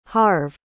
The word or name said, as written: harve